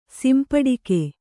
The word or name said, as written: ಸಂಪುಟಕ